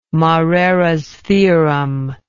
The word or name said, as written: moreras theorem